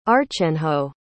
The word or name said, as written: archenhaud